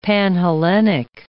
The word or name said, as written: Panhellenic